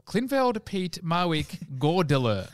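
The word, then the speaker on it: Klynveld Peat Marwick Goerdeler: Fun fact for you, KPMG stands for Klinfeld, Pete, Marwick, Gordiller. – You pronounce that very well.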